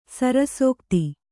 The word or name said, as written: ಸರಸೋಕ್ತಿ